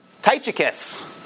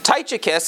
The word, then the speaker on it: Tychicus: Tychicus. I'm not 100% sure if you're supposed to hard pronounce the CH there, but I am. I'm English, so that's how it works. Tychicus is the one who is delivering these letters.